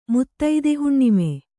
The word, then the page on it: ಮುತ್ತೈದೆಹುಣ್ಣಿಮೆ: kannada